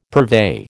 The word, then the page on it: purvey: American English